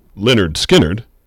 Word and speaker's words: Lynyrd Skynyrd: Next up, a little skit, little Lynyrd Skynyrd from pronounced Lynyrd Skynyrd.